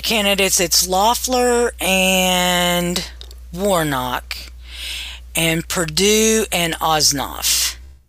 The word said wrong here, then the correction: Ossoff: I think Ossoff, but yeah. – Ossoff, that's right. I'm sitting here looking at it and said it wrong.